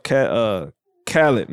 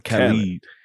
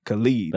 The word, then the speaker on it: Khalid: I love Khaled, not DJ Khaled, but Khalid. Khalid. – That's how I say his name. – I think it's Khalid.